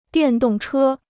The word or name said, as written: 电动车